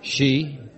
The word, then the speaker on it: ski: She? – That's the right way to pronounce S-K-I, George.